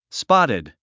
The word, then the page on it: spotted: spάtid; スパァティッド